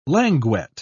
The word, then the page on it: languet: British English